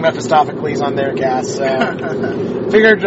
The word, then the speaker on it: Mephistopheles: Then I played, like, Nykvit, which I got to exile a Chains of Mephistopheles with Nahiri. – Oh, you said it right. – I've been making an effort to say it correctly. – Did you say Mephistopheles? – Mephistopheles.